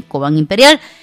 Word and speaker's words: Cobán Imperial: Michael Moreira, o bien Orlando Moreira, colocaba el 1-1 para el equipo de Cobán Imperial. Ahora sí lo dije bien, Cobán Imperial.